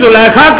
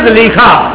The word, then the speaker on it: زلیخا: اگر کوئی زلیخا کہے یا زلیخا, تو تو کون سا نبی کا نام ہے جو غلط ہو گیا بھائی؟ اس میں غلطی کی کوئی بارہ تلفظ دو طرح پر ہے، زلیخا بھی صحیح ہے